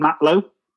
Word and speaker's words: Matlow: Okay, so my blog is Ultra Running Matlow, spelt mate lot, but pronounced Matlow. It's French for sailor.